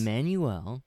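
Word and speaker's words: Emmanuelle: Next, we got Emanuele, or how do you say her name? – Okay, I know who she is, but I've always been kind of bad, probably. – Oh, if you're bad at saying it, I have no chance. – It's Emmanuel. – Okay, Emmanuel. – Shit, let me see.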